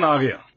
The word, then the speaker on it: Ariya: It's the Nissan Ariya.